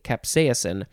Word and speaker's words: capsaicin: See, I said it correctly, capsaicin.